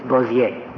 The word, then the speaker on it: Bossier: It is usually referred to, it has been in litigation, as Bossier Parish, but in Louisiana, it's Bossier. – Bossier, okay.